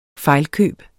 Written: fejlkøb

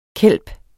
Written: kelp

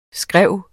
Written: skræv

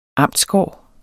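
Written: amtsgård

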